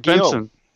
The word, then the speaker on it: Guillaume: Oh, Robert Guillaume.